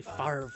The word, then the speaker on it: Favre: I'm never quite sure how to pronounce his name either because it's Favre. – Yeah. – But it's pronounced apparently Favre.